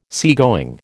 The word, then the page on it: seagoing: American English